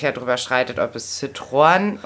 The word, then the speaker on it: Citroën: Und die zweisilbige Aussprache nebeneinander stehender, jetzt hätte ich fast Vulkane gesagt, Vokale, zum Beispiel wie in dem Wort Citroën.